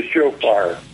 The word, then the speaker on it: shofar: You were, I think, at the Yom Kippur celebration, and you were blowing on a, what do you, how you pronounce this? – It's a shofar. It's a ram shofar.